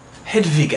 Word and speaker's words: Hedwig: Hedwig, actually, is how it's supposed to be pronounced. The W is a V.